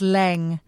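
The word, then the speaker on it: Jamie Laing: Wouldn't it be good to just cut him out and say, I'm afraid this podcast does not recognise Jamie Lang as being in season 18. – I love that you're Antoning him. You're still calling him Lang. Apparently it's Leng. You pronounce it Leng.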